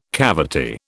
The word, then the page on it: cavity: British English